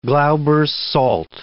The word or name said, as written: Glauber's salt